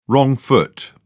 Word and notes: wrong foot: British English

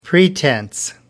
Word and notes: pretence: American English

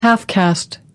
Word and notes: half-caste: American English

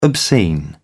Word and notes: obscene: British English

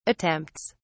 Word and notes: attempts: British English

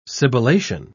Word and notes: sibilation: British English

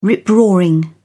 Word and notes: rip-roaring: British English

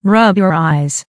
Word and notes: rub your eyes: American English

